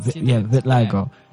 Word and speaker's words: vitiligo: Yes, vitiligo.